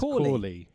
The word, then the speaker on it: Cawley: Hell Cawley or Cowley. – Cowley. – Or Cowley. Is it? You want to pronounce it incorrectly. – C or K? – C-A-W-L-E-Y. – C-A-W. That's Cawley.